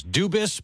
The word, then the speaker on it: Dubas: Now we're hearing that the pronunciation of the last name is Dubas, but stay tuned for more on that.